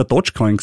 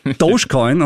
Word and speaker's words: Dogecoin: Ich habe immer Dogecoin gesagt, ich bin ja der alte Autofan. Das heißt Dogecoin und äh, Dogecoin ist für Musk anscheinend doch mehr als ein reiner Schmäh.